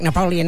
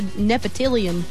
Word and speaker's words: Napoleon: Napoleon? It ain't that hard. – Napoleon. – Three syllables.